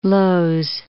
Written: Lowes

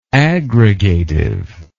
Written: aggregatively

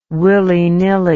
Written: willy nilly

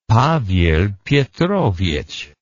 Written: Pavel Petrovich

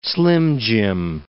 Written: slim-jim

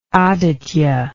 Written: Aditya

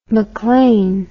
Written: maclaine

